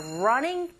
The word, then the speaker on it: running: Now try the second listening point, which is we are running towards the end. 자, 여러분, 여기서 running 할 때 learning 이라고 발음하시면 안 되죠. R 사운드 낼 때는 분명히 입을 이렇게 모으신 다음에 앞으로 내밀어 보세요.